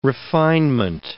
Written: refinement

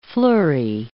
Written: flurry

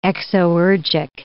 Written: exoergic

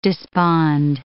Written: despond

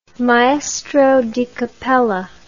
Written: maestro di cappella

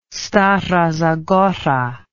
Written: stara zagora

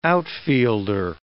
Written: outfielder

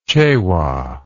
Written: Chewa

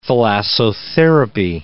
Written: thalassotherapy

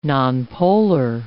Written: nonpolar

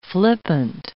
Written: flippant

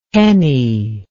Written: pennie

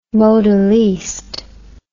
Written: modelliste

